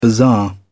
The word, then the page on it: bazaar: British English